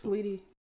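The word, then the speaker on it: Saweetie: It's Sweetie.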